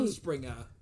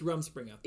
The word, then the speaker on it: Rumspringa: Rumspringa is my guess as to how that's pronounced. – I think you're right. – Rumspringa. It's Rumspringa.